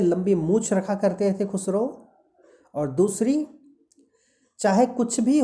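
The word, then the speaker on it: Khosrove: खुसरो spelling है दूसरे page में दूसरी लाइन में के H O S R O V E खुसरो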